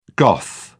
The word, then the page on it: goth: British English